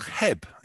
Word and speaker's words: Cheb: Heb. It's spelled C-H-E-B, but pronounced Heb.